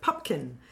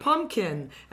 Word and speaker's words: Pupkin: Robert De Niro plays, as you say, a wannabe stand-up comic by the name of Rupert Pupkin, whose name is continuously mispronounced, Rupert Pumpkin, and all types like that.